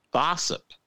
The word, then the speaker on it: Bossip: gossip, rhymes with gossip. It's Bossip.